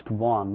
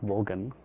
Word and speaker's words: Vaughan: This is pronounced Vaughan, not Vogan.